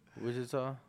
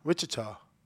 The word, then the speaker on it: Wichita: How you pronounce it? – Wichita. – Wichita.